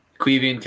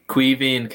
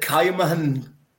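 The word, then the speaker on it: Caoimhín: And your point is, right, pronounce his name for me. – Quivian Kelleher. – Thank you. – Defo see that again? Again? – Quivian Kelleher. – I would have said Caimahan Kelleher. That's what I'd have said.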